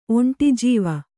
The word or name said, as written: ಒಂಟಿಜೀವ